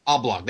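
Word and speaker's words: Oglop: Oblog, that was it. Rhymes with frog.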